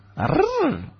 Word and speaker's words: Arjuna: No, es Arjuna. – ¿Con acento en la tercera o la cuarta R? – En la Juna. – En la Juna, vale.